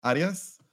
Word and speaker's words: Arias: Well, my name is Pablo Arias. Pronounce Arias.